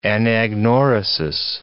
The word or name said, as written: anagnorisis